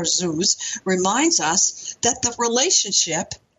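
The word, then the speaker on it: zuz (זוז): But it derives from a very interesting Hebrew root, sometimes pronounced zuz. Zuz, Z-O-O-Z would be spelling it phonetically, zuz, which means to move back and forth.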